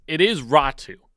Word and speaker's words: Räty: It is Atu Ratu. He has said that's how he wants it pronounced. It's how it's going to be.